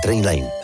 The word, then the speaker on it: Trainline: Se escribe mejor precio garantizado. Se pronuncia Trainline.